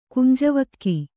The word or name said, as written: ಕುಂಜವಕ್ಕಿ